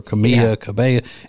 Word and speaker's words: Camila Cabello: Because I never know, because a lot of people mispronounce her name, call her Camila Cabello or Camila Cabello. It's Camila Cabello.